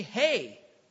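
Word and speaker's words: hey (ה): It looks like an H-E here, but it's actually a long A sound. It's actually hey, like hey.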